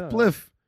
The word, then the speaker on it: spliff: Apparently, it's not pronounced spleef. – Spleef. – It's spliff.